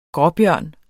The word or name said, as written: ursus